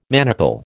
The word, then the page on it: manacle: American English